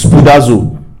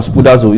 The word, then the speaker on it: spoudazo: The word study there is the word that you know, that means, it, actually, the word I'll spell it for you is spoudazo. Can you write it down? Spoudazo is the word spoudazo. S-P-O-U-D-A-Z-O. Spoudazo. S-P-O-U-D-A-Z-O.